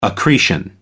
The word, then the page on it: accretion: American English